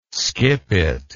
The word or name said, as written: skippet